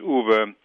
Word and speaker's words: Uwe: My first name is Uwe. It has three letters in it, and there are 300 million Americans, and it's an amazing thing that there are 300 million different ways of pronouncing three letters strung together.